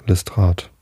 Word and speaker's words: Lestrade: Lestrade oder, na, was sagt der Chat? Habe ich das jetzt richtig ausgesprochen? Ja, Lestrade.